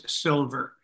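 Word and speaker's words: Sylver: My name is Max Silver.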